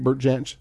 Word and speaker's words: Jansch: Am I saying that right, Bert Jansch? – Yeah, that's correct.